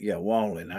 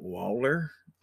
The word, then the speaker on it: Wallen: Wallen. I kept saying his name wrong for a while. I was like, Waller? Walker, maybe?